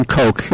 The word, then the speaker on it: Koch: So in New York, you tend to mispronounce that. So. – That's right. – Okay, so yeah, it's Citizen Coke is the name of the movie, and they have a website.